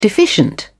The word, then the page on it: deficient: British English